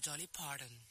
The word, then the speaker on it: Dolly Parton: ¿cómo se dice Dolly Parton? – No, señor, Dolly Parton.